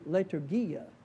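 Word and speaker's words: liturgia (λειτουργία): This is pronounced in the Greek liturgia. What does that sound like in English? Liturgia. Liturgy, yeah. So liturgia.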